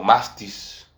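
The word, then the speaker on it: mastis (μάστιξ): En el original griego, se pronuncia como mastis, que quiere decir latigazo.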